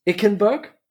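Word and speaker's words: Eichenberg: Plus dazu Ickenberg, so wird er nämlich ausgesprochen offensichtlich.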